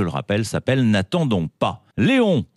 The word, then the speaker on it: Léon: Léon, c'est une fille et ça va vous épater, vous allez voir, elle est suédoise et surtout, ça se prononce Léon.